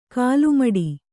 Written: ಕಾಲುಮೆಟ್ಟು

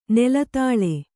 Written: ನೆಲದಾಳೆ

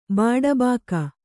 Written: ಬಾಡಬಾಕ

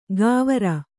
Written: ಗಾವರ

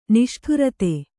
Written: ನಿಷ್ಠುರತೆ

